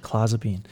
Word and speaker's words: clozapine: So it this is a drug that's pretty similar to cl- clozapine. I say that correctly? – That's you did that one. time. – Clozapine.